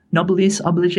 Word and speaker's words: noblesse oblige: And on the one hand, the kind of like noblesse, I'm going to mangle this pronunciation, noblesse oblige, I think it is.